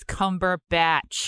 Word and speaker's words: Cumberbatch: I'm going to make you pronounce his name right if it is the last thing I do. It is Cumberbatch.